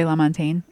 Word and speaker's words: LaMontagne: Ray LaMontagne.